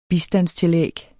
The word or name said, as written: bistandstillæg